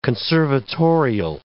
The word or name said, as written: conservatorial